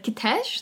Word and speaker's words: Kitezh: And now when we watched this Werner Herzog thing, he said Kitesh. So we're like, oh. – I think Kitesh probably sounds... – We were saying it wrong the whole time.